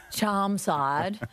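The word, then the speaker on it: Chermside: And I think Charmside, that's how you northern suburbs people pronounce it, isn't it? – Charmside, like Nunda Heights.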